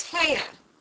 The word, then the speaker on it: Hare: His name is Hare." – He pronounced it so as to rhyme with mayor.